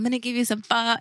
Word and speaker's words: pho: I know, because everybody's like, pho. No, girl. Forget about that. It's pho.